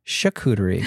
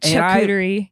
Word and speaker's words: charcuterie: Anyone else put off by them pronouncing the hard ch in charcuterie? Where I'm from, everyone pronounces it charcuterie. And I want to die because I looked it up and that's the correct pronunciation.